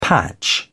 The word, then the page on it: patch: British English